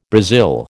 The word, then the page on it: Brazil: American English